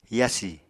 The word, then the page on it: yasi: apatani